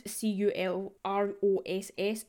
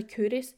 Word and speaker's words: Culross: If you look it up, it will, it's spelled C U L R O S S, so it looks like it's pronounced Culross, but it's actually Kouris.